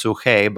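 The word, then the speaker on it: suche (ψυχή): The Greek word, if you look at it transliterated, looks like psyche. It's pronounced suche, but that's the soul part of us.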